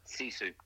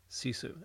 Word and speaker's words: Sisu: And on that, you tie that in nicely, because how do you pronounce, is it Sisu? – Sisu. – Sisu. And that's a Finnish word, and that sums up your coaching philosophy, your concept.